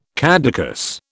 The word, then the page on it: caducous: British English